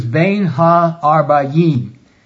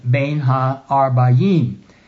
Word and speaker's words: Bein Ha-Arbayim: The Hebrew is Bein Ha-Arbayim. Now that is spelled as three Hebrew words, B-E-Y-N, and then H-A, and then A-R-B-A-Y-I-M, Bein Ha-Arbayim.